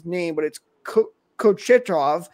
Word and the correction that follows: Kochetkov: Peter Kochetkov. – Kochetkov, thank you.